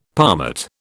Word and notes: palmate: British English

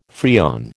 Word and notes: Freon: American English

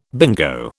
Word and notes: bingo: British English

